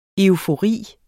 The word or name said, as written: eufori